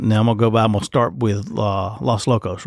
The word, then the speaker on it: Los Locos: Am I pronouncing it right? – That is Los okay. – Locos. – Yep. Okay, Los Locos. – Yep, there you go. – I didn't put the right pronunciation on Los.